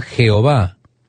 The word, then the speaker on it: Yahvé: Hay quienes dicen que se pronunciaba Jehová, pero hay quienes dicen que se pronunciaba Yahvé. Todo lo que tenemos originalmente, estimado oyente, son consonantes. I, H, YHWH. Reitero, YHWH. Quedaron fueras las vocales, así que no sabemos cuál era la pronunciación correspondiente. Pero muchos eruditos piensan que era Yahvé.